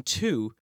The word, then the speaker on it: two: That silent W in two is a baby maker.